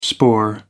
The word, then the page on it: spoor: American English